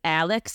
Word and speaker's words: Alex: And then we have Richard Alexander Murdaugh, known as Alex, but for some reason everyone pronounces it Alec.